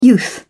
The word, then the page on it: youth: British English